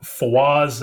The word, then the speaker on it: Fawaz: People can follow me at Fawaz, that's spelled FAWAZAZIM, all lowercase.